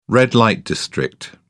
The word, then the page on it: red-light district: British English